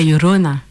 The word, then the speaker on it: Llorona: Vous le diriez comment vous ce double L, c'est la Llorona, Chorona. – La Llorona.